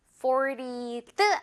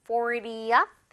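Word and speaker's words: fortieth: This year is our 40th 아니고 40th anniversary. Anniversary 하면 기념일이니까요. 40주년이야 라는 거겠죠. 어때요 여러분? 이렇게 숫자 10 단위에서 Y로 끝날 때는 어를 하나 넣는 이 포인트만 기억해도요.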